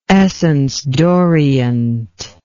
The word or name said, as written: essence d'orient